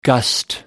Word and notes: gust: American English

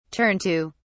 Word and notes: turn to: British English

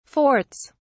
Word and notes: forts: British English

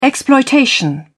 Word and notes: exploitation: British English

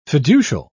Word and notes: fiducial: British English

